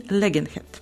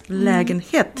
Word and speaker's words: lägenhet: А, я vill skriva på ett kontrakt för att hyra din lägenhet. – Да, отлично. Lägenhet. – Lägenheit, mm-hmm. – двойное ударение.